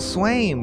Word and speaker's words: Swaim: I'm one of your hosts, Michael Swaim, which rhymes with frame.